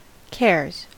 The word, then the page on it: cares: American English